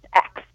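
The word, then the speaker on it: AIX: There is a Provence rosé that's easy to remember, and it's X, A-I-X. It's pronounced X.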